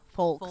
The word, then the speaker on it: folks: We say that we pronounce the L, folks.